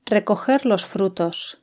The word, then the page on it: recoger los frutos: European Spanish